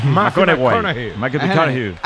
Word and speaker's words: McConaughey: Matthew McConaughey. – Sí. – Un futbolero, le gusta. Hay que ir corrigiendo la pronunciación. – McConaughey.